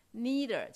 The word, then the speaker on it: neither: Neither 就是 neither 哈，同一个怎么念都可以。